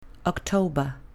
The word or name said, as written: october